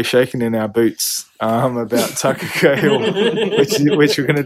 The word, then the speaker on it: Takakā: Because I looked at it and went Takakā, but we kind of emphasized the second syllable. Yeah. They get into the last one, so it's Takakā.